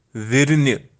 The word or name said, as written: virni